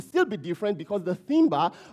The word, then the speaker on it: timbre: Another word for that is timbre. Timbre is spelled T-I-M-B-R-E, timbre.